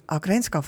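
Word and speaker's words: Ågrenska: et eh, nüüd vist peaksime ka selgitama, eh, aidake, mis asi on Ookeanska või kuidas on õige hääldada Ookeanska või Aagerenska fond? Eh, sellega on niimoodi, et kuna me oleme sihtasutus Eesti Agrenska fond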